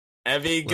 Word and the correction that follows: Evangelion: Oh, Neon Genesis Evangelion came to Netflix with all 26 of its episodes.